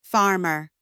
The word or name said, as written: farmer